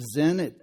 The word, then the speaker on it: Sin: And this says Wilderness of Zin. I know it's spelled S-I-N, but it's Wilderness of Zin.